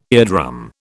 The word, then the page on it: eardrum: British English